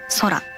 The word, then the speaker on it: 宇宙: 宇宙と書いて空と読むんですけど、